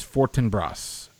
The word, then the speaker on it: Fortinbras: No, the gentleman is a prince from a different country, and his name is Fortinbras. Fortinbras. And that's, I just had to look up its pronunciation to validate that I was pronouncing it correctly according to Google.